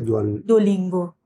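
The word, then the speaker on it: Duolingo: یعنی اپلیکیشن دوالینگو یا دولینگو.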